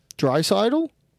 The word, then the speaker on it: Draisaitl: Connor McDavid, Leon Draisaitl, just great people, just doing big things in the world. – Draisaitl? Yeah, that's how you pronounce that.